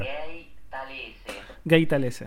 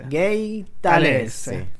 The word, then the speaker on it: Gay Talese: Gay talese. Gay talese. – Hay que, hay que pronunciarlo hasta con ese.